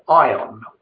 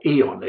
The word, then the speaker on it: ion: and ion, or eon, if you prefer that pronunciation.